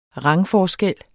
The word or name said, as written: rangforskel